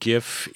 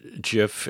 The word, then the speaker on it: GIF: I always have pronounced it GIF, even though I know the originators prefers JIF. And in the study I did, I understand that the originator's opinion of how it should be pronounced has been totally overwhelmed by usage, and so the GIF is the way to go.